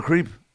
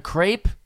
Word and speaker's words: crepe: And I was like, you mean like a crepe? – That's what I said. I go, you're mispronouncing it. I go, if you're going to be in this country, say our pastries correctly.